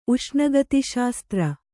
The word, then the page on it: ಉಷ್ಣಗತಿಶಾಸ್ತ್ರ: Kannada; uṣṇagatiśasatra